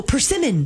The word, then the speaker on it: persimmon: Parsimon? – Oh, persimmon.